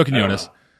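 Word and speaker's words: Quiñones: So, the art in this book is done by Joe Quinones. I think that's how you pronounce his name. I think that's the name right. – Okay. – Yeah, it is. Joe Quinones.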